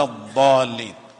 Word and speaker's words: الضالين: يعني انطقها بالضاد اخت الصاد وليس بالضاء غير المغضوب عليهم ولا الضالين.